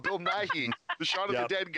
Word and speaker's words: Nighy: No, but, and since I've confused it before, it's Bill Nighy, not Bill Nye.